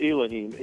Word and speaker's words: Elohim: I pronounce it Elohim, E-L-O-H-I-M.